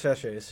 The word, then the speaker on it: sachets: Is that how you say it? – Yeah, simmer pot sachets.